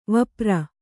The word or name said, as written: ವಪ್ರ